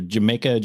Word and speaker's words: jamaica: It's called Jamaica, I think, or Jamaica, because, you know, J is not a hard J, makes it like an H sound in Spanish.